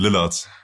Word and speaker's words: Lillard: Ich habe hier Damian Lillard, wie würde er ausgesprochen? – Ich würde sagen Damian Lillard. – Lillard, genau.